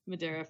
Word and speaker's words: Madeira: I'll be in Madeira. It's pronounced Madara.